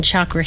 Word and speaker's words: chakra: Well, I'll tell you which is the correct pronunciation. It's chakra. – Chakra. – Mm-hmm. Chakra. – Chakra. All right. Yeah. I'll bring that to yoga. – It's a Sanskrit word. It's not an English word. It's a Sanskrit word, and that's the way it's pronounced.